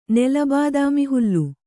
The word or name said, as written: ನೆಲಬಾದಾಮಿಹುಲ್ಲು